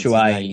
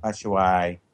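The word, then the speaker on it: Batshuayi: No, we looked at Bashawi. I can't say. What? How do you pronounce Bashuai. – His name? – Bashawi. – Bashuai.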